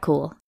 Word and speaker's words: Kuhl: I'm Kat Kuhl, and I can be found on Twitter at @WolvesAreCool, with Kuhl spelled like my last name, K-U-H-L, or @CampaignPod for the show.